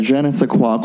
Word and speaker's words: je ne sais quoi: Like, he is such a, there's a je ne sais quoi quality about him.